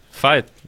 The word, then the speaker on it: Veit: Valerie spricht man wie W, Valerie, und Veit spricht man wie F. Und trotzdem schreibt man es mit V.